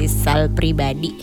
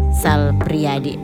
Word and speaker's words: Sal Priadi: Dulu saya menyebut Sal Priadi bukan Sal Priadi sahabat Fox, saya salah membacanya menjadi Sal Pribadi atau Sal Priambodo ya. Tapi ternyata namanya adalah Sal Priadi.